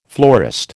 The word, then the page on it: florist: American English